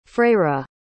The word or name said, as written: freira